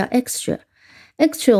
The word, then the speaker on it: extra: 那么如果我们仔细听，extra，那么是两个音节，x 啊，不要直接写成字母 x，哦，它前面是有元音的，所以 x 就是 ex。 T R A tra extra，那么我们要知道，在 s 后面，虽然这里是 x 啊，但它读发音里面是 cus，对吧？在 s 后面的清音、清辅音常常是要被读成浊辅音的，所以这里应该是 extra 啊，我们读出来是 extra。